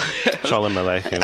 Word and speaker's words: Sholem Aleichem: So Bialik College, King David and Sholem, I'm not going to pronounce that properly. – Sholem Aleichem. – Aleichem, yeah.